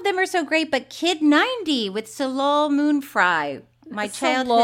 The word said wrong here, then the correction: Soleil: Wait, did I say it wrong? How do you say it? Soleil.